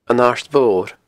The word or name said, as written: An Aird Mhor